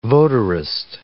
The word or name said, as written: votarist